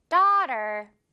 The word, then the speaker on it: daughter: She has a young daughter. Great. One more time. She has a young daughter. 아주 잘했어요, 여러분. 큰 소리로 잘 따라했습니다. 이 AUGHT 발음, 맛있는 떡 발음 확실하게 우리 정복할 수 있겠죠?